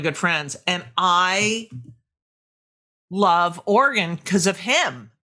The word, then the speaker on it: Oregon: Notice how I say it correctly, Oregon.